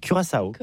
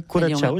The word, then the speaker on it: Curaçao: Curaçao. Donc comment on prononce ça? – Curaçao. – Ah oui, Curaçao, j'aime bien.